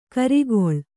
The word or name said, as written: ಖರೆಗೊಳ್